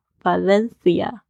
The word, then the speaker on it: Valencia: 啊，我第一站呢就是去了 Valencia 瓦伦西亚，二点五个小时距离巴塞罗那，所以这一天呢我就索性跳上火车，第一站呢就到了 Valencia。你在讲这个西班牙语的时候呢，c 要变成的声音，就是有点像我们 th 的这个发音，所以会咬舌头。 Valencia。